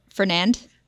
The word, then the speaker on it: Fernand: It is pronounced Fernan. – Fernan. – Like there's no date. – Fernan. – Fernan. So I think it's maybe like Francais. Fernan.